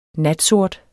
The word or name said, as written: natsort